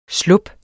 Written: slub

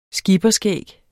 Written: skipperskæg

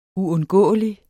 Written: uundgåelig

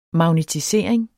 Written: magnetisering

